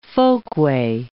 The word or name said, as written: folkway